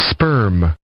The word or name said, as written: spurm